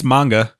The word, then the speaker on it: manga: But is it manga or manga? – It's manga. – Okay, that's how the Japanese would say it.